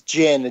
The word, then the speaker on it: djinn: Andred says, a mother and daughter getting menaced by bombs, political repression, family anxieties, and an evil djinn? I don't know how to pronounce it. I'm sorry. – It's djinn, a genie.